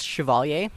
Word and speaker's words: Chevalier: I'm Nicholas Chevalier. Okay. That's last name is spelled C-H-E-V-A-L-I-E-R.